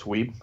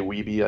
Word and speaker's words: Weeb: Yeah, it's Weeb. A lot of people say Weeby. I don't really correct them because it's kind of spelled that way.